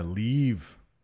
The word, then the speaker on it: leave: Yeah, leave is a little different from leaf. Leave. Hey, I need to leave in a few minutes. Oh, that's a pretty leaf on the tree. So just a little different pronunciation there.